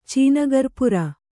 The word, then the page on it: ಚೀನಿಕರ್ಪೂರ: cīni karpūra